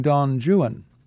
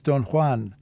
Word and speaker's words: Don Juan: It was in this period that he wrote some of his most famous works, including Don Juan. Yes, yes, I know. The proper Spanish pronunciation is Don Juan, but the British are notorious for imposing their own quirky pronunciations on words of foreign origin.